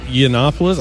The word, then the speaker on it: Yiannopoulos: Yiannopoulos.